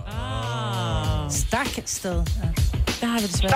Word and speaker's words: Stagsted: S-T-A-G-S-T-E-D. – Stavsted. – Stavsted, ja. – Det er forkert. – Stagsted. – Det er også forkert. – Stagsted. – Stagsted. Stav, fortæl os det. – Stakste.